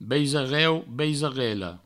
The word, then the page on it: baisareu, baisarela: beisareu, beisarello; Provençal